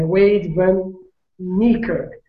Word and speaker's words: Wade Van Niekerk: É Wade Van Niekerk.